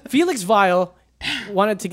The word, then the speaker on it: Weil: His name is Weil. – Ooh. – With a W, though, so you have to pronounce it Weil. – Well, yeah, because W's are pronounced V's, like Steve Weil, Volkswagen.